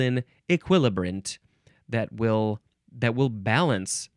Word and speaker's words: equilibrant: Equilibrant, you can also say E, still emphasizing the second syllable, equilibrant. You can also say equilibrant, equilibrant, spelled E Q U I L I B R A N T.